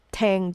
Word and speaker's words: Tang: So you will see the, per- the spelling as T A N G, or you call it Tang Dynasty, but it, it's pronounced Tang, um, Tang Dynasty.